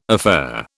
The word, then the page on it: affaire: British English